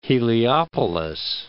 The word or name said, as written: Heliopolis